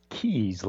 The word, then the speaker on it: Keas: It's K-E-A-S, but it's pronounced Keys, like car keys.